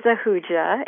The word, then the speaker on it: Ahuja: My last name is Ahuja, and it's spelled A-H-U-J-A, and that's actually East Indian from India.